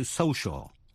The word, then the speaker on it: social: Số 3 là C phát âm là sh. Trong những tiếp vĩ ngữ như C-I-A-L, như trong chữ social, thuộc về xã hội.